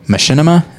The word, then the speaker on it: Machinima: I was curious, cause I know that you are at the moment, you're taking a video essay class, and then talking about Machinima, is that how you say it? – Yeah.